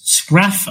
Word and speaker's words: Scharff: It's a story of Hans Schraff. Hopefully I've said that right, S-C-H-A-R-F-F.